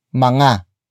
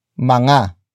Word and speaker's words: mga: But before that, we have mga. Mga. Mga. Mga is spelled as M-G-A.